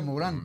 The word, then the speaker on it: Morin: ¿Se escribe Morin o Morin? – Morin. Morin. – Y se pronuncia Morin, claro. Sí. Pronuncia Morin, ¿no?